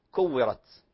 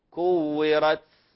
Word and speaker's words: كورت: مثلا في كلمة كورت، أحيانا إذا بالغنا في النطق على الحرف فقد تنقلب التاء إلى سين، كورت، عطلت، وهكذا، وهذا غير صحيح، فلا نبالغ في الضغط على هذا الحرف.